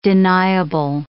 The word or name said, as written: deniable